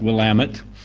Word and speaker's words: Willamette: But I live in the north end of the Willamette Valley, pronounced Willamette.